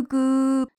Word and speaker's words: goo: New goo, blue goo, gooey gooey blue goo, new goo, gluey gluey。好，这一段我们来找点新花样吧。这儿有许多蓝色的胶，那么 g o o 念作 goo 发长音，指的是粘性的物质胶的意思。